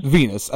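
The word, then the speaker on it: Venis: Venus. I think cause it was supposed to rhyme with penis, but who cares?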